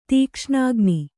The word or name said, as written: ದಕ್ಷಿಣಾಗ್ನಿ